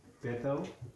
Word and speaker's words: Bethel: To me, so far you've done well to say my name, Bethel. So yes, I'm Bethel.